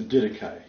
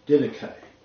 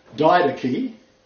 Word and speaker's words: Didache: Now, it's the Didache. You may write this down, D-I-D-A-C-H-E, Didache. I have heard it pronounced Didache. Didache. But it's not. It's Didache.